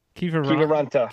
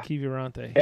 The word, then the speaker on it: Kiviranta: I keep getting his name wrong. Keevy Vonte. – Keevy Ronta. – Kiviranta. Kiviranta.